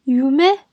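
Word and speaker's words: ゆめ: 我们现在这里先讲一下，就是日语当中“ 梦 ”“yume” 啊这个词，因为呢，“you and me”，我们把 “you” 和 “me” 那个 “ryu” 加上 “me”，就是日文的“ 梦” 的发音 “yume”，“yume” 梦。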